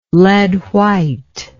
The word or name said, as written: lead white